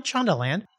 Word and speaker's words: Shondaland: Shondaland? – Yes, Shonda Rhimes has a publication called Shondaland.